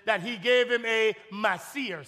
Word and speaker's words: maaser: The word is spelled M-A-A-S-E-R. That's the Hebrew word for it, Masir. M-A-A-S-E-R.